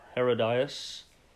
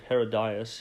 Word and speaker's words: Herodias: So verse 3, "For Herod had laid hold of John and bound him and put him in prison for the sake of," I believe it's pronounced Herodias. So "for Herod had laid hold of John and bound him and put him in prison for the sake of Herodias, his brother Philip's wife, because John had said to him, 'It is not lawful for you to have her.'